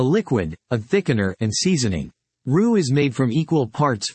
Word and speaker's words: roux: Roux, pronounced roux, is the thickening agent used in three of the five French mother sauces, espagnole, béchamel, and velouté.